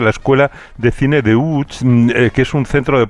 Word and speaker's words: Łódź: Aunque se pronuncia Łódź, se escribe Lodz, por si alguien lo busca Lodz, en Google. Casi no tiene nada que ver la pronunciación.